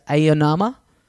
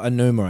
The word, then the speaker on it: Aonuma: So it was also interspersed with, they had these like little mini interviews with Oh, God, how do you say his name? The producer, Aonuma? – Oh, Anuma, I think. – Anuma, yeah. Him. – Eiji Anuma, I believe, yeah, is how you say it.